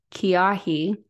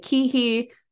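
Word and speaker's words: Keahi: It's Kiahi is like how they pronounce it. And it means the fire, literally. That is the name. – Love that. – I was like, huh, that's creepy. But but it worked. But yeah, some people pronounce it Kihi. Some people pronounce it Kihai. I pronounce it Kai, like Kai Parker on The Vampire Diaries.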